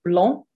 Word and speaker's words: blanc: So blanc, which means white. I think English people tend to try and say blanc, like Mont Blanc, the mountain, but it's not as blanc. Blanc.